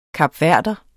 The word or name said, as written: kapverder